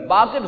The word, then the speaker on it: باقر: اصل لفظ ہے باقر، ناصر فائل کے بدن پر ہے، باقر صحیح لفظ ہے، اکثر لوگ اس کی غلط تلفظ کرتے ہوئے نظر آتے ہیں۔ کون کہہ رہے ہیں؟